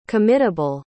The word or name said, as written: committable